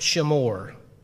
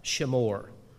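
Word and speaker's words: shimmur: The word solemn there, I'm reading from the New King James, that word in the Hebrew is pronounced shemor. It's S-H-I-M-M-U-R, shemor.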